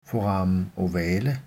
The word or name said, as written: foramen ovale